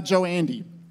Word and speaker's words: Djoeandy: This is Dr. Omar Joandy. If you're wondering how to pronounce his last name, it's actually quite easy if you drop the D at the beginning.